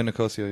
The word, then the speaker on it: Nicosia: Yeah, Nicosia, yeah.